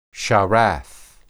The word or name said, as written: sharath